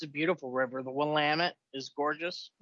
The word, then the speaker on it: Willamette: It's Willamette, goddammit.